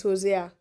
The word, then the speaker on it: Hosea: Yes, it's Hosea.